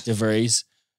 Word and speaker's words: De Vries: De Vries.